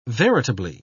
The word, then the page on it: veritably: British English